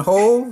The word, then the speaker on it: whole: The W in whole has always been silent.